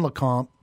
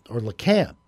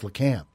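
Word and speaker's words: Lecompte: There's not much in LeConte, or LeCamp, actually, is how they say it there. – Really? – Yeah, it's LeCamp.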